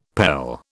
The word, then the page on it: pele: British English